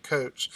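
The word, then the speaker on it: Kocs: Yeah, I looked it up and it actually comes from a little town in Hungary that was named Coach, actually spelled something like K-O-C-S-I, but they pronounced it Coach.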